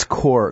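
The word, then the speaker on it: corps: But thinking about the linebacking corpse now, you're going to have... – It's core. The P is silent, Boss. – Okay. – A corpse is a body that, like habeas corpus, okay?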